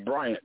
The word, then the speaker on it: Bryant: My name is Bryant. That's spelled B-R-Y-A-N-T.